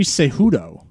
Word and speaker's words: Cejudo: Henry Cejudo? Is that how you pronounce his name? – Henry Cejudo. – Cejudo. Okay. The J is silent.